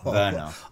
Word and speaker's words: Werner: You're not saying Werner Herzog, right? – Yes, I am, but it's pronounced Werner.